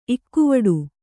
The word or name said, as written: ಇಕ್ಕುವಡು